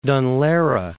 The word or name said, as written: Dun Laoghaire